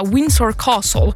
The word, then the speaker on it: Windsor Castle: Och det handlar om ett antal poliser som misstänks för att ha somnat när de skulle vakta Windsor Castle, där som den brittiska kungafamiljen befann sig. – Exakt rätt uttal.